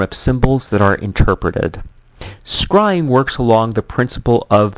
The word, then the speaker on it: scrying: Scrying, pronounced to rhyme with crying,